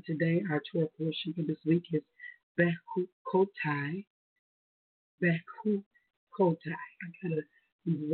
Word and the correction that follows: Bechukotai: words, I'm sorry, sometimes it's Bechukotai. There we go. I need to put the emphasis on the right syllable there.